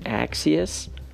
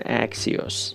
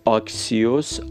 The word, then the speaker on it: Axios: اکسیس خود من هم به این شکل تلفظ میکنم برخی دیگه میگن اکسیوس ولی آکسیوس